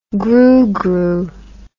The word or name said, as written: groogroo